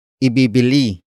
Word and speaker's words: ibibili: The first word is ibibili. Ibibili. Ibibili. Ibibili is spelled as I-B-I-B-I-L-I.